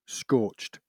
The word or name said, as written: scorched